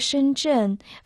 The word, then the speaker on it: 深圳: Название города Шэньчжэн в китайском языке состоит из двух иероглифов. Первый иероглиф «шэн» произносится в первом доне, в переводе на русский «глубокие», «глубоко». Второй иероглиф «чэн» — это четвертый дон, в переводе «ганава».